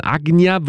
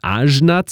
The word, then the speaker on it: Ajna: Ajna wird es eigentlich im Sanskrit ausgesprochen. Die meisten würden wahrscheinlich Ajna zu sagen. A-J-N-A geschrieben, ist aber gleichzeitig auch ein belgischer Produzent. A-J-N-A geschrieben, der sich hier mit Mischa zusammengetan hat.